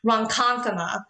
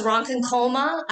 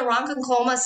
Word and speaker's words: Ronkonkoma: there's a town, on a side note, there's a town called Ronkonkoma. And I thought it was Ronkonkoma.